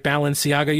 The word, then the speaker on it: Balenciaga: TJ, you know how to pronounce this word, right? Balenciaga. You're a big fashionista. I think TJ knows this stuff. – Yeah, you said it exactly right. How I would have said it.